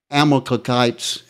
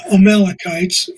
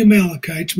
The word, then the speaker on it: Amalekites: The future King David led a successful mission against the Amalekites to recover, and this is quoting, all that the Amalekites had carried away. And I'm sorry, I'm mispronouncing that so badly. – Yeah, Amalekites. Yeah, no, it's easy if you're not. It's the Amalekites, but you're doing great.